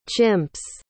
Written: chimps

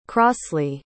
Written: crosly

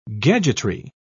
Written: gadgetry

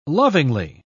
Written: lovingly

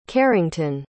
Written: carrington

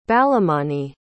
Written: balamani

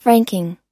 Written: franking

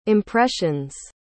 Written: impressions

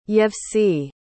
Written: yevtsye

